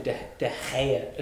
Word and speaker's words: De Gea: What do we think of David De Gea? As the commentators insist on pronouncing it, it's De Gea.